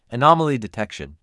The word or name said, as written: Anomaly detection